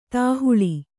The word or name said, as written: ದೇಹಳಿ